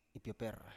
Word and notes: ipioper: Biak

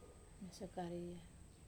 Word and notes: masoi kariria: Wamesa

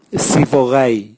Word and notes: sivogai: Raga

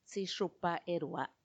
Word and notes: tsixupaerua: Macuiltianguis

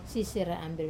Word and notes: sisera amber: Wamesa

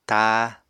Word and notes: táa: apatani

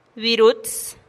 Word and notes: birutz: TdVZ

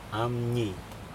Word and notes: amNii: Aneityum

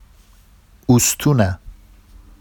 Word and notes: ustuna: Vafsi

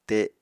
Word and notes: te: apatani

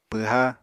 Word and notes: pwha: Apatani

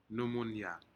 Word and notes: numunyak: Aneityum

